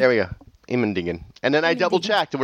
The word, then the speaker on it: Immendingen: I went to the Immendingen test facility. And is that really how you say that, or did you mutilate that? – Nope, that's how they say it.